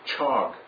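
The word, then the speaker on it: chog (חג): But the Hebrew word for feast, the actual Hebrew word for feast, is Strong's 2282, and it's pronounced chog. C-H-A-G, but it sounds like dog. Chog. It rhymes with that.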